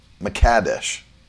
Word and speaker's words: Mekadesh: And this is where you see Jehovah Makadesh. And really, that's I think that's the pronunciation. The is on the cad, Makadesh.